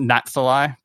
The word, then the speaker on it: Natsali: It's pronounced Natsali, but it's not spelt like that.